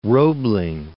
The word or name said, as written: Roebling